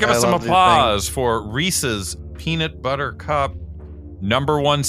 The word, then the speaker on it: Reese's: The Reese's, or as some people say, Reese's. – That's what Kenan Thompson says. Adam. – Well, it rhymes with PC. – Reese PC.